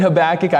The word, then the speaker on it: Habakkuk: And so I'm going to be pronouncing it Habakkuk. I understand there are other ways to pronounce it. That's kind of the standard English pronunciation.